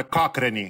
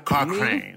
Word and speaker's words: Cochrane: Se eu quiser ser bem chato, vou falar, não, é Cochrane. Cochrane. Inglesado é Cochrane.